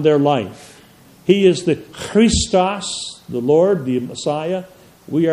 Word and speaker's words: Christos: That's how they would have said in those days, because the first letter, CH, for Christ is pronounced Christ, Christos. Can you do it? Christ, Christ. It's a guttural.